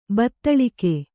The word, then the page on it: ಬತ್ತಳಿಗೆ: Kannada